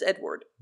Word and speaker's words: Edouard: Yeah, what a weird way of saying Edward, but it is Edward. – Yeah. – Edward. It's spelled E-D-O-U-A-R-D.